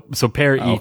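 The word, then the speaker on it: Peryton: So Periton, okay, is the correct pronunciation according to my notes here.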